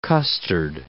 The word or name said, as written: custard